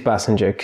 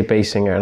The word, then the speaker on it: Basinger: It is Basinger, because a lot of people say Basinger, and I hate that pronunciation.